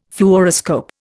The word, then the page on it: Fluoroscope: Indian